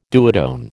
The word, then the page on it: duotone: American English